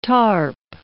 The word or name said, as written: tarp